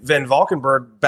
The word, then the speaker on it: Van Valkenburg: I just actually hit Kevin Van Valkenburg back up on Twitter.